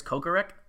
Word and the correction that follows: Kocurek: Is that how you say his last name? – I think it's Kocurek. – Kocurek. Okay, so K-O-C-U-R-E-K.